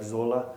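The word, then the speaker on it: Sölle: L-L-E. So I believe the correct pronunciation or the German pronunciation would be like Zola.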